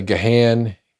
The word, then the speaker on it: Gahan: It's spelled G-A-H-A-N. So people say Gahan, Gahan, it's Gahan. Like G-A-W-N is the way he says.